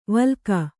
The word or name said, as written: ವಿಲೋಕ